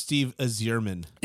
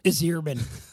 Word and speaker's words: Yzerman: I believe that it's pronounced Steve Yzerman. – Yzerman. That's how Bill Clinton pronounced it.